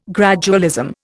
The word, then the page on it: gradualism: Indian